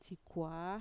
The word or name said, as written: txikuaa